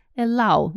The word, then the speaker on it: allow: Первое слово, в котором все делают ошибки, это allow, разрешать. Allow, его все произносят через O, мы так делать не будем. Разрешать, allow.